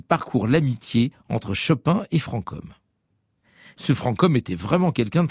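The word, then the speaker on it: Franchomme: Tiens, justement, à propos de prononciation, faut-il dire francom ou franchom? Allez, on va dire francom.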